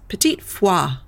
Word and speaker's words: petit four: But I went to a little bakery out here, a little French bakery, and they're pronounced petit foie. – Petit foie. – I was like, what, oh my God, I've been saying it this horrible way this entire time.